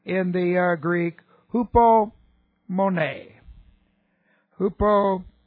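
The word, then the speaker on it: hupomone (ὑπομονή): But here we have H-U-P-O-M-O-N, long E, hupomone.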